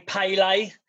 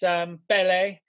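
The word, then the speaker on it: Pelé: It annoys me how Americans say Pele, because it's Pele.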